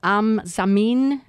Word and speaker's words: Am Zamin: That CD called Am Zamin. Am I saying that right? – Exactly right. And Am Zamin, Common Ground.